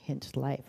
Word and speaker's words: Hinchcliffe: I'm saying that right, am I? Yes. No, I'm not saying it right. Hinch Life, right? No C. Hinchcliffe. Okay.